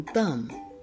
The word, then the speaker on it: thumb: But one of the sounds is silent, like in thumb. You don't really hear the B, but it's there.